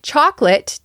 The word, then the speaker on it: chocolate: Chocolate, to me, has two syllables. Chocolate. The second O you see when the word is written is silent. So if you learned through reading, you might say chocolate as three syllables and not chocolate.